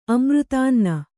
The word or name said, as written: ಅಮೃತಾನ್ನ